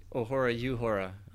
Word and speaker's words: Uhura: I must say, too, I like the way you pronounce Uhura. I'm trying to think, what was that episode in TOS where Kirk does the same thing? So you're not wrong. It's pronounced that way by some folks.